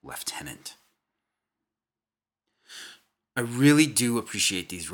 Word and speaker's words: lieutenant: Quote, "Please God, stop pronouncing lieutenant the way it's spelled and the way Americans pronounce it," which I don't criticize. "It's lieutenant in the British and Commonwealth armies," unquote.